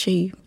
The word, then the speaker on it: Chee: I kept Chi. And I usually have to spell it, you know, C-H-E-E.